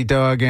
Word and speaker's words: Dawg: Well, like I said, my name is Nate Diggity Dog, and the dog part is spelled D-A-W-G, not D-O-double-G.